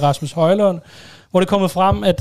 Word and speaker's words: Højlund: Men de har lige glemt, at han stades med Ø, selvom han ellers meget udtrykkeligt har fortalt, hvordan Højlund... – Højlund. Høj udtales.